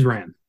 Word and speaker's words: Ran: And on Oscar Sunday, they'll be taking a look at the Kurosawa-directed epic historical drama, Ran. Please tell me that's how you say it. – It is Ran, yes.